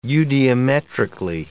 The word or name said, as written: eudiometrically